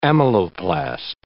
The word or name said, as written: amyloplast